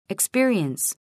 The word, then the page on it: experience: ikspíəriəns